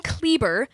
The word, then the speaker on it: Kleber: My last name, Kleber, It rhymes with Justin Bieber.